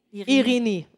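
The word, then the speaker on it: Irini (Ειρήνη): Irini? – Irini. – Okay, Irini.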